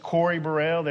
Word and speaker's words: Kory Burel: My name is Corey Burrell. That's spelled K-O-R-Y-B-U-R-E-L.